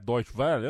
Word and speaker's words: Deutsche Welle: O, o Gerd assinou no, no site da Deutsche Will Eu falei certo? Deutsche Welle? Eu é difícil, você sabe que é difícil. Deutsche né? Welle. Né? Deutsche Welle.